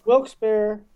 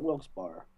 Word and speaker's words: Wilkes-Barre: And it's Wilkes-Barre, not Wilkes-Barre. – It's Bear? – Bear. – Not Bar. Okay.